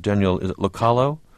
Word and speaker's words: Locallo: And what about the judge in courtroom 302, Judge Daniel Locallo? – Yes. – Am I pronouncing it correctly? – Yes, that's right.